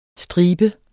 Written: stribe

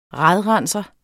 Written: RADRENSER

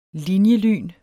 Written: linjelyn

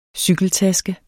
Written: cykeltaske

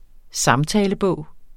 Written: SAMTALEBOG

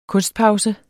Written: kunstpause

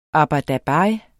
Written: ABER

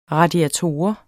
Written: radiator

